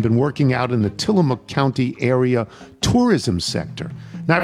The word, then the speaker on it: Tillamook: I was recently on the county's tourism board, and the Tillamook Creamery, pronounced closer to Tillamook, with the O-O being closer to a short U vowel, is our biggest draw of tourists every year.